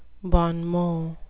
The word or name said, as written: bon mot